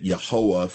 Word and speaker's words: Yahweh: And we put vowel sounds to it, as Brother Stephen explained a few weeks ago. And so we say Yahweh, or if you take the vowel sounds from Adonai and put them under the consonants for Yahweh, we would get Yehoah, from which we get Jehovah.